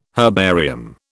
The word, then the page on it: herbarium: British English